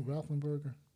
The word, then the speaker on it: Roethlisberger: And I thought Roethlisberger's years, Roethlisberger, how do you say that boy's name, Roethlisberger? – You really messed me up.